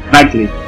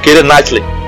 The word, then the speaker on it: Keira Knightley: Diferente de A Teoria de Tudo, a atriz Keira Knightley, eu não sei pronunciar o nome dela. – Knightley. – Keira Knightley.